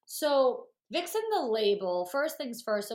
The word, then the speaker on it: Vicsen: Vixen, like Vixen. And like Vixen being sexy, like okay. V-I-X-E-N. So this is Vic, V-I-C-S-E-N. Vixen. – Okay, you literally have a graphic explaining this, and I was still struggling. So thank you. – Yeah, no, it's Vixen, like how we've always heard, like what a Vixen is.